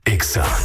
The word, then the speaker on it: Exa: No se dicen éxitos, se dice Exa. – En todas partes. – En todas partes. Ponte Exa FM 97.3.